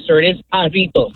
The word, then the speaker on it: Jarritos: But no, it's Hispanic, so it's pronounced jarritos. – Jar toast. – As I said, jarritos, a popular Mexican brand of fruit flavored.